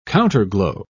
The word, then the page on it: counterglow: British English